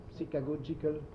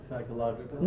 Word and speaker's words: psychagogy: And It is a techniques for psychagogy. How do you say that? Psychagogical technique. – Psychological? – No, psychological. Psychagogic. Psychagogy. Well, it's a technique for conducting souls.